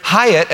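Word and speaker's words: Hiett: My name is Peter Hyatt, and we spell it the same way.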